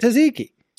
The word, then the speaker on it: tzatziki: We found last week, we were at Target, surprise, and we found some vegan tzatziki.